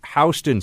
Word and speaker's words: Houston: It is, in fact, Houston Street here in New York City, even though it's spelled Houston.